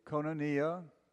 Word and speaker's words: koinonia: And it's pronounced, hey, koinonia, the fellowship of the Holy Spirit, to hagiou pneumatos.